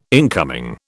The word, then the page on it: incoming: British English